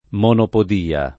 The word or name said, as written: monopodia